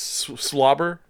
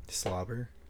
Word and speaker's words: SWLABR: It's Slobber, S-W-L-A-B-R. – Oh, yeah. – That's how you say it? – 45 seconds. – Slobber.